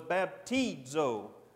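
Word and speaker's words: baptizo (βαπτίζω): Again, you put a D after the I. That's just how the Greeks pronounce words. So it's baptizo.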